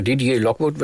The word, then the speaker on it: Didier Lockwood: Und ja, einer der renommiertesten Jazzgeiger überhaupt, das ist wahrscheinlich, ich hoffe, es richtig ausspreche, Didier Lockwood. – Ja, Didier Lockwood, würde ich sagen, ist aber leider schon verstorben, also 2018.